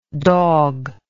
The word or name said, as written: dogless